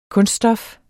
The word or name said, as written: kunststof